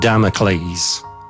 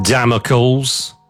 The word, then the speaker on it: Damocles: sleep token and Damocles. Not Damocles, as I've heard people pronounce it, honestly. Familiarise yourself with the legend of the sword of Damocles.